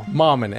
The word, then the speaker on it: Mammen: Mammen, M-A-M-M-E-N, pronounced like ramen.